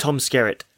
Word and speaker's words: Skerritt: So we see Tom Sirzik. How do you say his name? – Tom Skerritt.